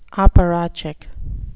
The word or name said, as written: apparatchik